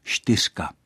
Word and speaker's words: štyřka: A v mluvené češtině, jak na Moravě, tak v Čechách, můžeme slyšet běžně výslovnostní variantu štyřka. Tady se místo hlásky č objevuje hláska š, a to z důvodu pohodlnosti při vyslovování té souhláskové skupiny.